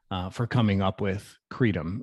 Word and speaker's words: Creidim: It's C R E I D I M, pronounced Creidim. You know, it's an it's an Irish term that means believe.